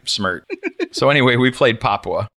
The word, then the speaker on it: Papua: I kept calling it Papua or something like that. It's Papua.